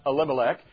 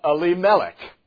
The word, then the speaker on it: Elimelech: Now, the main players here, because Elimelech goes there and dies, but his name, how we normally pronounce it, is not Elimelech. It is Elimelech.